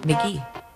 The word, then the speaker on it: MK.GEE: It is spelled MK.GEE. So how do you think you say that? MK.GEE. Well, good news. In 2018, he released an EP which was titled Pronounced McGee. MK.GEE is pronounced McGee.